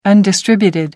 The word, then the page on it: undistributed: American English